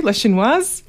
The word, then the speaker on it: La Chinoise: Rue du Table follows the release of Godard's film La Chinoise. Is that right too? La Chinoise. – Yes.